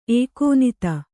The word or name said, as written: ಎಕ್ಕನಾದಿ